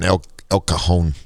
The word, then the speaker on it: El Cajon: You know, when you come from the Midwest, H's and J's are pronounced a little different than they are here. Versus La Jolla and El Cajon, anyway.